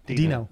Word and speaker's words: Deno: Right, and the same thing with is it Deno? I, I never know how to say it. Yeah. Dino. Dino.